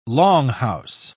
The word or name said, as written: longhouse